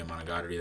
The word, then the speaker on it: Monogatari: I don't know how to pronounce Monogatari? – It. Yeah, Monogatari, that one.